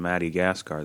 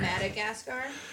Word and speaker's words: Madagascar: We sat and watched the Penguins of Madagascar this morning.